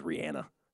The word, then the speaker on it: Rihanna: Yeah, she says the proper pronunciation is Rihanna.